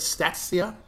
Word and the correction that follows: Statista: Stati- Statista. Statista. – Yes. Like Batista.